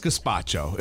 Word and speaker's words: gazpacho: So what is gazpacho? Am I even saying it correctly? – You are saying it correctly.